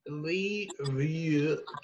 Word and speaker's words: Leverkusen: How do you pronounce that again? – Leverkusen. Werder Bremen versus Leverkusen.